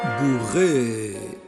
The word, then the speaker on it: bourrée: Bourré. Bourré o bourré se escribe bourré, bourré, con dos es al final.